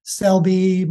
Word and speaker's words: Selbie: Selbie, my last name is spelled somewhat unusually. It's S-E-L-B-I-E rather than the more typical S-E-L-B-Y.